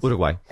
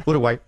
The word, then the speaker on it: Uruguay: Uruguay. – Exactly. – Uruguay.